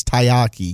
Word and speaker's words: taiyaki: And I say it's taiyaki because that's how it's spelled. T-A-I-Y-A-K-I.